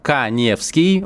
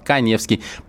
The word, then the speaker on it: Каневский: Для этого вам нужно подписаться на мой инстаграм Эдуард Каневский. У меня инстаграм с галочкой, фамилия пишется через А. Эдуард Каневский.